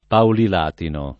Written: Paulilatino

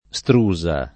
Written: strusa

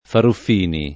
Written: Faruffini

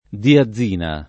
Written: diazina

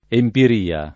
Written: empiria

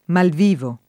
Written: malvivo

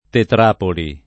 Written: tetrapoli

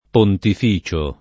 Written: pontificio

